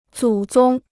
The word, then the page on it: 祖宗: zǔ zōng